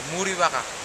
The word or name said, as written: muriwaka